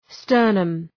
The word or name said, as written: sternum